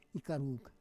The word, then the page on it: ikaruk: Roon